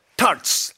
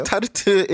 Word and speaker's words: tart: Tarts. – Egg tarts. – 이게 한국에서는 타르트라고 발음하는데. – Right. But if you say tarte in America, they will be confused. Tart. – 뭐라고? 어떻게? – Tart. – Tart. – Right. T-A-R-T.